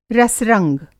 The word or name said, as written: रस-रंग